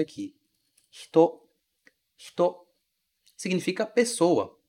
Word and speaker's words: hito: Veja que eu não falo hito, hito. Esse I quase some. A gente fala hito, hito, hito. É assim que o japonês realmente pronuncia essa palavra. Esse i quase desaparece.